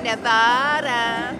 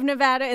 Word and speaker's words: Nevada: the state you live in. How do you pronounce it? Nevada. But I laughed when Trump said Nevada.